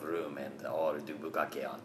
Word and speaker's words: bukkake: If you dare look up the history of bukkake, correct pronunciation by those, bukkake.